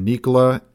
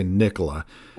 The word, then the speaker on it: Nikola: So a company called Nikola had an eventful week on Wall Street, and I'm going to try to stick with the pronunciation Nikola as much as I want to say Nikola.